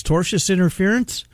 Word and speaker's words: tortious interference: Tortious interference? – You pronounced it right.